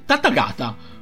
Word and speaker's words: Tathagata: Così alla fin fine eh, viene sfidato da un Tataga, no scusate, da un Buddha che si chiama Tathagata. Ho detto bene, sì, Tathagata.